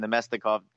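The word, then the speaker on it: Namestnikov: And we get, you know, Neskinov gets traded. And what's your take on the deal there? I butchered his name. I'm sorry about it. – Nemestikov. Thank you.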